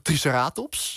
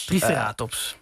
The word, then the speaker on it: Triceratops: Ja, het is dus officieel zeggen we Triceratops. – Triceratops. – Het is net die klemtoon die, zeg maar, op de andere plek terechtkomt.